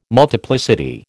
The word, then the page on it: multiplicity: American English